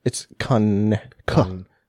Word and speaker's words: Conecuh: It's Conecuh. – What'd you say? – It's Conecuh. – Okay. – Conecuh. Ridge Distillery. – They're like Hanukkah? – They're available since 1946 and legal since 2001.